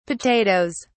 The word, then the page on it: potatoes: British English